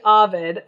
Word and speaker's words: Ovid: And I looked it up, and it is indeed Ovid.